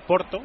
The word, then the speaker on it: Porto: Ahora parece que se ha recuperado, pero bueno, 1-0 gana el Porto, que se dice así, el Porto, minuto 13 de la primera parte.